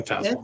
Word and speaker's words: Tazewell: It has a lot of silent E's in it. So it's actually pronounced Anne Tazewell. – Sorry about that. Anne Tazewell. – It's fine, but it's spelled like Tazewell.